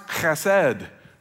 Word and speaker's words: hesed: and the word is hesed, H-E-S-E-D, hesed. It's pronounced chesed. Just don't say that in front of somebody close. Chesed, the Hebrew is chesed.